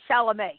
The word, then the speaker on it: Chalamet: It's Chalamet. He said you don't pronounce the last T.